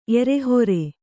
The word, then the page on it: ಎರೆಹೊರೆ: erehore